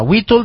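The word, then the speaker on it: Witold: Whittle Gilarski está perdido, es un piloto de parapente en el noroeste colombiano.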